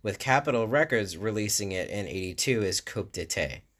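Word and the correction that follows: coup d'état: Coup d'etat, sorry.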